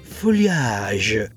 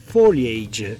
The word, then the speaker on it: foliage: Che scritto, leggendo letteralmente, è foliage, Bene, i più danno una connotazione romantica e leggono questa parola come se fosse una parola francese, chiamandola foliage, mentre in realtà questo è un termine inglese che si legge foliage e vuol dire fogliame, un'abbreviazione del termine completo che è fall foliage, che vuol dire fogliame autunnale.